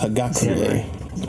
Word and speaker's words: Hagakure: it's pronounced Hagakure.